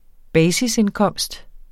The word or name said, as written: basisindkomst